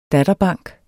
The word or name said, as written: datterbank